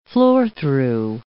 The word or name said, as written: floor-through